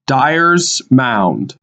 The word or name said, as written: Dyers Mound